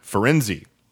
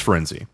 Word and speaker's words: Firenze: Firenze. He hires Firenze the centaur to teach divination. – I thought it was just Firenz. – It's Firenze. So, at least according to the movie pronunciation, I'm going to go with that.